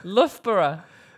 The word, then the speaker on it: Loughborough: Loboro? – Loughborough. – What? Loughborough? – Loughborough. Loughborough. – You know how I would spell that? – How? – Luffbruh.